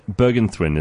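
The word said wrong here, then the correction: Bergenthuin: Is that right? Did I say your name right? – Bergentine, actually, yeah. – Bergentine. – Yes. – Okay. So it's T-H-U-I-N and it's teen.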